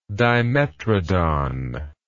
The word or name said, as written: dimetrodon